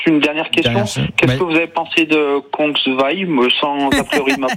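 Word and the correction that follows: Csongvai: Alors, première chose, ça ne se prononce pas comme ça. – c'est compliqué, parce que ça s'écrit S-O-N-G-V-A-I, mais donc, on pourrait dire... Oui, CS, pardon, en plus. Et ça se dit Chongwai. – Chongwai. – Chongwai, d'accord. – Comme si vous mettiez TCH devant pour commencer le mot.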